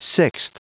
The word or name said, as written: sixth